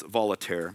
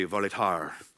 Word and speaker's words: Voltaire: If I want to say it like the American I am, it's Voltaire, but it's probably Voltaire, the French writer.